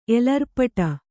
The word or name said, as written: ಎಲರ್ಪಟ